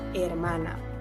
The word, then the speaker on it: hermana: Achte darauf, das H ist im Spanischen immer stumm, also una hermana.